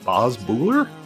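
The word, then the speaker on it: Boz Boorer: Was it Boz Buehler? Did I say that right? Yep.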